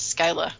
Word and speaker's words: Scylla: I've always said Scylla. – I say Scylla because I think, like, scepter, scimitar, scythe. I can't say that that's actually in any way meaningfully an approximation of Greek pronunciation.